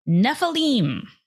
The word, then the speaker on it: Nephilim: Well, I did listen to some videos, so it is Nephilim.